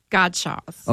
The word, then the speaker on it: Gottschalk's: Gottschalk's.